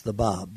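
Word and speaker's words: Báb: And he, in his language, it's pronounced the Bab, but for this purposes, he called himself the Gate.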